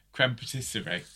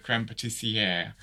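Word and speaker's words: crème pâtissière: It annoys me every week when somebody says... creme patisserie instead of creme patissiere.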